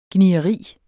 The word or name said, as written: gnieri